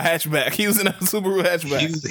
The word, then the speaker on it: Subaru: How do you say that truck name? – Subaru? – Subaru.